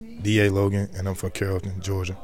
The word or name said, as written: DA Logan